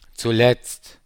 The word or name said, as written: zuletzt